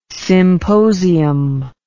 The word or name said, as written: symposium